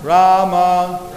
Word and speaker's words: Rama: Pronounce each name distinctly. Hare Krishna Rama. It says each name is two syllables.